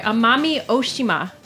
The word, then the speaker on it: Amami Oshima: We heard about his time in Amina Oshima. I think that's how you pronounce it. I'm sorry, Amami Oshima, an island off the coast of Kagoshima, making Koyuto, which is black sugar.